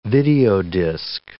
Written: videodisk

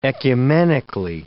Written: ecumenically

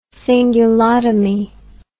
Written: cingulotomist

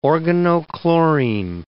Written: organochlorine

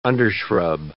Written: undershrub

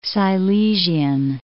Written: Silesian

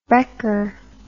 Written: brekker

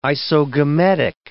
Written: isogametic

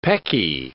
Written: pecky

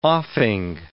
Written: offing

